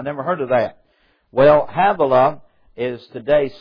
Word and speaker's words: Havilah: that's pronounced H-A-V/A/L-A, Havilah, unto Sir, S-I-R, Sir, that is before Egypt, as thou goest towards Assyria, and he died in the presence of all his brethren.